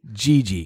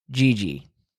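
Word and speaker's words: Jiji: J-I-J-I-I. – No. Don't tell me how to spell it. – Oh, Gigi. – Gigi. Got it. – Think of Bee Gees. Gigi.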